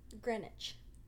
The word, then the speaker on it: Greenwich: Greenwich. – Greenwich, yeah. There's a W in it. – I know, but it's a silent W.